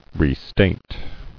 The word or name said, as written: Restate